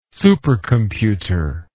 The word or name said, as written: supercomputer